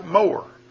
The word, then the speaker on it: myrrh: And the word spelt in the Hebrew and pronounced in the English is like more, like M-O-R-E. But in the Hebrew, it's M-O-R. It's more or more.